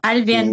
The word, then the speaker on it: Albian: Tama ba yung pagkaka-pronounce ko ng ninyo, ma'am? – Apo. Albian si, po.